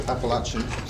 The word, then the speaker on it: Appalachian: I think that's right. Appalachian. Sorry. I was giving lessons on Scottish pronunciation in my pipe class this morning, all the different names and trying to get people to say them. So that's one back on me.